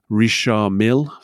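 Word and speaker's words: Richard Mille: is Where Watch Meets Weapon by Richard Millet. Is that the correct pronunciation? Richard Mill.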